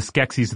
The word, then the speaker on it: Skeksis: Now, you're not pronouncing sexy in a funny way. You're saying like Skeksis, yes. – Like in the Dark Crystal. – Right. The Skeksis of the Dark Crystal, where they're kind of like long, slender, snouted, and very toothy looking.